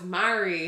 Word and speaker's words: Marie: No, it's Mary. – But it's spelt like Marie. – Leave my grandmother alone. – How do you spell Marie? What? – M-A-R-I-E.